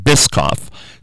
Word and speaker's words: Biscoff: Now, I will say that actually when I ordered this, I said Bischoff Blizzard, and they corrected me and said Biscoff.